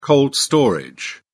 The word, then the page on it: cold storage: British English